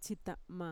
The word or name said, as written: txita'ma